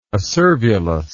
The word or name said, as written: acervulus